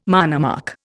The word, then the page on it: monomark: Indian